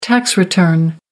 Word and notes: tax return: American English

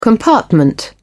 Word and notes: compartment: British English

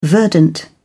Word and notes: verdant: British English

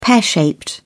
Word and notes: pear shaped: British English

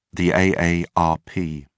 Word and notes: aarp: British English

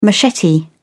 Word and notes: machete: British English

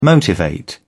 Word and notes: motivate: British English

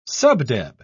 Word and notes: subdeb: British English

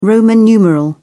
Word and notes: roman numeral: British English

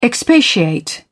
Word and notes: expatiate: British English

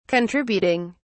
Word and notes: contributing: British English

